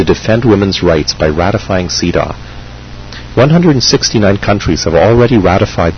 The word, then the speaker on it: CEDAW: This treaty is also known under a longer name, the Convention on the Elimination of All Forms of Discrimination Against Women, frequently abbreviated to CEDAW, pronounced CEDAW.